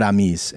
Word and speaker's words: amis: I think the S is ami. Yeah. So that means friends. It would be spelled as we would spell it, A-M-I-S. The way that it was being taught in schools, in some schools, by some teachers that were just trying to be inclusive, was A-M-I.E.S, which would include both the masculine and feminine way to say that word. You still say it ami. – If it was, you would spell it wrong, but if it was A-M-E-S, that would be a feminine word, I'm guessing.